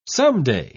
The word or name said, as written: someday